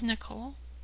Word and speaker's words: Nikole: This is Nicole. – Nicole. Is that, how would you spell it, Nicole? – N-I-K-O-L-E. – N-I-K-O-L-E.